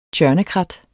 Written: tjørnekrat

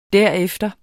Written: derefter

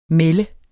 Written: Atriplex

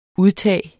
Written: udtag

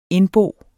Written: indbo

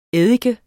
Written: eddike

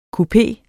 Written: kupé

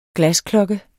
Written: glasklokke

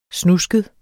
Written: snusket

